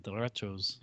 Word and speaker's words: derechos: It's a Spanish origin, so Spanish I'm sure it's more like derechos. – You probably roll that R a little bit in derechos.